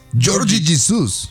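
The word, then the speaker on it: Jorge Jesus: ¿Cómo se dice Jorge Jesús en portugués? – Georgie Jesús.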